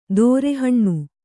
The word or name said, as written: ದೋರಹಣ್ಣು